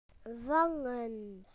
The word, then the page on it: to catch: British English